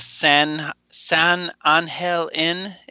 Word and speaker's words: San Angel Inn: it's going to be the San Angel Inn. Is that how you pronounce that correctly? – Yeah.